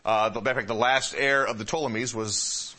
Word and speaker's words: Ptolemies: In Egypt, down here, you had the Ptolemies, all right, silent P, Ptolemies.